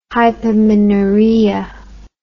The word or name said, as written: hypomenorrhea